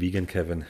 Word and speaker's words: Vegankevin: Ich sag immer eigentlich Vegan Kevin, hört sich besser an. International.